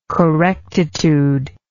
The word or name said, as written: correctitude